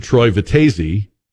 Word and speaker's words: Vitezzi: And so it would really be better, I guess, according to Troy Vitezzi, which rhymes with crazy, if I just turned her loose.